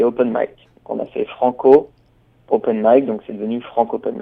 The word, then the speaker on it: Franc'Open Mic: Alors moi, je vais déjà essayer de comprendre le nom de votre de votre concept France Open. – Alors Franco Open Mic. – D'accord, donc déjà je le prononce mal.